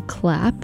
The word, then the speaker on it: CLAAP!: And I should note that that version that I just played, that was a remix by the mysterious duo Clap, that's spelled C-L-A-A-P exclamation point.